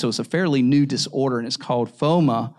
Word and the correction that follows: FOMO: or FOMO, if I pronounce that right. It's FOMO, and it's the fear of missing out.